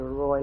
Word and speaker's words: Roi: It's spelled R-O-I, but it's pronounced R-O-W, Roy, like we have a name, somebody that's named Roy.